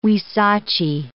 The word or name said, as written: huisache